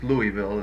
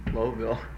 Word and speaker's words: Louisville: That's the way they pronounce it. – How do you pronounce it? Is that the way you pronounce it? – Often, well, it's Louisville is the way I used to pronounce it until I ran across people from Louisville.